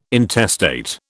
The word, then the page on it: intestate: British English